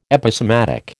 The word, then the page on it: Episematic: American English